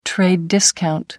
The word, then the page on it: trade discount: American English